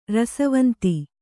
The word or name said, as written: ರಸವಂತಿ